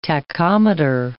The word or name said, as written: tachometer